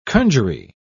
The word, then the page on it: conjury: British English